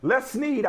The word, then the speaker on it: Les Snead: Les Snead, I said this right.